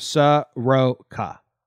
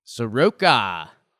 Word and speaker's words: Soroka: Looks, according to Baseball Reference, it's pronounced Soroka. – Soroka.